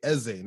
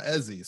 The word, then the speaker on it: Eze: I'm not just claiming him, like, he is Igbo, and he's told everyone to pronounce his name properly, Eze, not Ezzy.